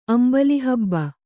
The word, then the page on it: ಅಂಬಲಿಹಬ್ಬ: Kannada